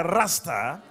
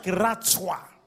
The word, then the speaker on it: Ratsah: But it's not pronounced like a Rasta. It's pronounced like Ratsah.